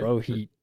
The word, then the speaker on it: Rohit Chopra: Rohit Chopra. Chopra? I guess I said that right. – Yeah, it looks right. – Rohit. – That's how I would pronounce it.